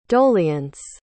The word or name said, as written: doleance